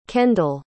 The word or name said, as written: kendall